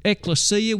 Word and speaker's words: ekklesia: The original Greek word, ekklesia, E-K-K-L-E-S-I-A. Now that's the way it would look to you and me in English, but of course in Greek it'd be pronounced in a way somewhat like the way you and I would say ekklesia.